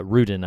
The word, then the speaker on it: Rudin: Well, uh, producer Scott Rudin, uh, Rudin, I believe is how you say his name, he had final cut for it, and he sided with Alex Garland and what Garland wanted to do.